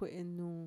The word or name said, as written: kuè'è nùù